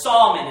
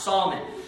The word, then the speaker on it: Salmon: It's Salmon is how it's pronounced. Salmon.